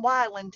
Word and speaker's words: Wyland: Well, my son was just not, he, he he's on Wyland time which is odd that it rhymes with island. So island time, wyland time.